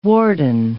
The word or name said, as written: warden